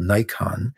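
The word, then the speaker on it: Naikan: And the other approach that we teach is something called Nikon, which is pronounced like we pronounce the camera Nikon, but spelled differently.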